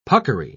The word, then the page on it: puckery: British English